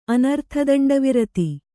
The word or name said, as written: ಅನರ್ಥದಂಡವಿರತಿ